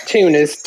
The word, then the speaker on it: Tunis: Tunis, T-U-N-I-S.